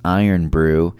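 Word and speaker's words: Irn-Bru: It says it's the other national drink, pronounced Iron Brew.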